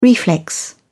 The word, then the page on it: reflex: British English